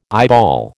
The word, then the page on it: eyeball: American English